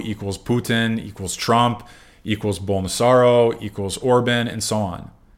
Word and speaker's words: Bolsonaro: Bolsonaro, yeah.